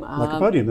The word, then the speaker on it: Lycopodium: Lycopodium, that's it. – Oh, there we go. L-Y-L.